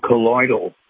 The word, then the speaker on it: colloidal: Is that the collateral silver you're referring to? Or it's called colloidal. – Colloidal. I can't hardly pronounce it.